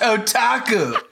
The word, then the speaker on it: otaku: I told you it's pronounced otaku.